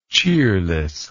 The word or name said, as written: cheerlessness